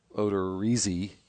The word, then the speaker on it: Odorizzi: Odorizzi. – Whose name I just pronounced differently the second time because that's the correct way. Baseball Reference has informed me.